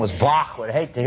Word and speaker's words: Bach: But you've all used it, if you pronounce Johann Sebastian's name correctly, in German, it is Bach. It is not batch. It is Bach. It isn't back. Or even Bach. It's Bach. Bach.